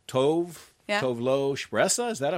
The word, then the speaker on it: Spressa: And so Flora Cash are Cole and Spressa. – Oh, that was actually a really good pronunciation. – See, yeah. – Okay. – Yeah. – So you got the SH, the Spressa.